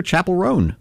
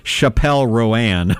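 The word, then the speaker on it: Chappell Roan: It seems pretty straightforward to me, but apparently, people have all sorts of interesting ways of mispronouncing this individual's name, including Chappelle Roan.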